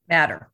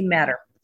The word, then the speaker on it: Mader: In fact, Eric Lee hyphen in there, Mader, and there's two dots over the A. How do you pronounce his name? – Mader. – Very well. – Eric Lee Mader.